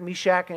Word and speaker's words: Meshach: It's about three guys named Shadrach, Meshach, and Abednego. And that's really how you say it.